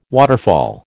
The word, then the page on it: waterfall: American English